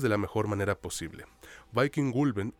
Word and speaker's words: Vikingulven: Concuerdo contigo y ahora sí, pues no alarguemos más el asunto, iniciemos con la película Viking Gulben. Así es como la pronuncian en Noruega al parecer,